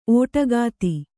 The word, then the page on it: ಒಡಗತೆ: oḍagate